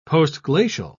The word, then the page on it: postglacial: British English